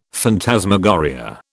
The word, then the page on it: phantasmagoria: British English